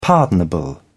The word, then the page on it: pardonable: British English